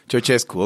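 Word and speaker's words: Ceaușescu: Ceausescu, ok.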